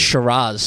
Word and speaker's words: Shiraz: Now, you know why I'm going with Shiraz? – Why? – Because that's how it's supposed to be pronounced.